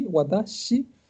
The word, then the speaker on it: watashi: en verdad recuerden que es con s que se pronuncia como Shi, Watashi, y eh, ella dice Boku en una parte.